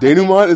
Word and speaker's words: denouement: Denouement, is that how you say it? Yeah, oh, okay.